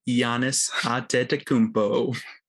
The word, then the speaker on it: Giannis Antetokounmpo: Goes by the name of Giannis Antetokounmpo.